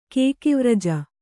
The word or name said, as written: ಕೇಕಿವ್ರಜ